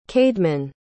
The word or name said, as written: caedmon